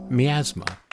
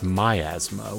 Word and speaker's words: miasma: But before I get too carried away, let's address the question of pronunciation. Merriam-Webster, the official dictionary of Word of the Week, says it's miasma, which is just wrong. – Why? – It's like laying claim to one's own asthma. – It's mine. It's all mine. – The Oxford English Dictionary gives the British pronunciation as miasma.